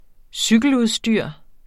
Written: cykeludstyr

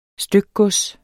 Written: stykgods

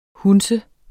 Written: hundse

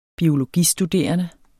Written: biologistuderende

